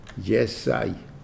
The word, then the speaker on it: Yishai: It's an I sound, I sound at the end. Yesai, Yesai. That's the way it is pronounced.